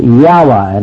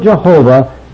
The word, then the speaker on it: Jehovah: And sometimes you'll find a pronunciation given of Yahweh, and it's really the same word. This would usually be used by some eight ball that thinks he's found some kind of a new truth. But the word Jehovah is a perfectly proper pronunciation for us of this word.